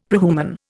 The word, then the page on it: prehuman: Indian